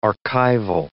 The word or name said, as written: archival